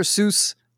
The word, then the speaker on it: Seuss: Um, so at top of our list, Dr. Seuss. Apparently, the right way to say his name is Dr. Seuss, which sounds stupid, kind of, wow.